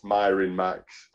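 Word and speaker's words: Mirin Max: Um, it's it's short for well, it's it's meant to be pronounced Myrin Max, but not a lot of people get the reference.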